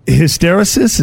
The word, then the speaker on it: hysteresis: Uh, hysteresis is that the correct uh, way to uh, say it? I said it hysteresis. – wrong. Hysteresis. Okay. Yeah. – Hysteresis, Close.